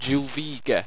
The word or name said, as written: juvigah